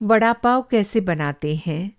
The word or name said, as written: वड़ापाव